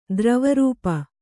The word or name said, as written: ದ್ರವರೂಪ